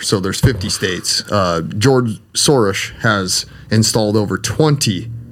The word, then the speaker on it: Soros: but George Soros is, Shorish is actually how you say it, but George Soros is, he has installed over 20 state attorney generals and backed them up and financed their campaigns.